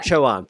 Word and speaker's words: Chowan: And uh, why why are you at that river, the Chowan? How, how do you say that? – Yeah. The Chowan, yeah. Chowan, thank you.